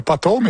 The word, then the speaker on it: potholes: Bravo, potholes, mais il faut bien prononcer le P.